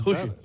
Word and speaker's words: Juche: Sí, es que lo pronuncia mal. Juche.